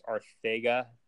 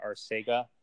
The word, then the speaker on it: Arcega: Did you know that that's how you pronounce it? It's Arthega, not Arcega.